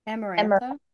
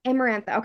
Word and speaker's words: Amarantha: Amarantha. – Amarantha.